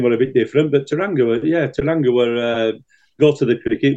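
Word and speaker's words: Tauranga: Because the last time I was in Taranga, that's how you pronounce it, by the way, Smithy, I had a party of a lifetime.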